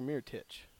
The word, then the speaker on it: Mirotić: Is it Mirotić or Mirotić? – Mirotić.